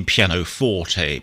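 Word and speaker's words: pianoforte: And of course, in the music trivia section, that should have been piano forte, but Eloquence mispronounced it.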